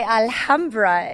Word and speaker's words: Alhambra: Alhambra, I should pronounce the H. – That's okay. Alhambra is the Arab word, okay? Yeah. Because we have the H in middle and it's coming from Arabic. But in Spanish, we don't pronounce it. This is because you can say Alhambra or Alhambra, okay?